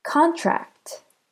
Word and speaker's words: contract: Contract. Contract. Contract. Do you hear how con is stressed?